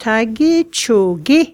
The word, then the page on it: Tage Cho Ge: Yukon River